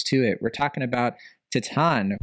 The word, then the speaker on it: Titane: As you all know, our French pronunciation could use a remedial class for sure. We've been calling it Titanie, Titane, it's been ridiculous, but yeah. It's Titan.